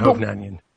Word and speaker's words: Hovnanian: Stephan um, Hovnanian. Hovnanian, oh, that's it. – Yeah. See, and it's actually phonetically the the way it's spelled, so I just have to keep it that way.